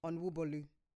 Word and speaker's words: Onwubolu: The Yoruba people, please pronounce Andrew's surname for me. Onwubolu. – Sounds good, man. – Andrew Onwubolu. – Andrew Onwubolu.